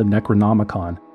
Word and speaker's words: Necronomicon: Nor did he realize that what he referred to as the original cult Bible, the Necronomicon, which he repeatedly mispronounced as the Necromonicon, was a complete work of fiction invented by sci-fi horror writer H.P.